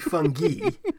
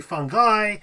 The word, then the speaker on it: fungi: The I would be pronounced E, so it'd be fungi.